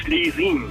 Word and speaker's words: Schlesien: Genau wie gesprochen, auch geschrieben, wird aber ausgesprochen Schlesien.